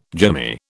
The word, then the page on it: jimmy: British English